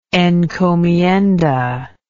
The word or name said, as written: encomienda